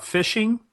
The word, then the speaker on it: phishing: So let's take one step back and really define a couple terms here because we're going to talk about phishing and spear phishing. And it sounds just like exactly what it is, even though it's spelled different. It's spelled P-H-I-S-H-I-N-G.